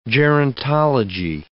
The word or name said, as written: gerontology